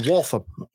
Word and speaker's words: Waltham: Is that Waltham or Waltham? – Waltham. – Waltham? – Waltham. – The second syllable? – Waltham. Yep. – All right. That's how you people say it. That's how P-Roll says it. That's how it's said.